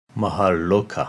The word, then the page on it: Maharloka: Sanskrit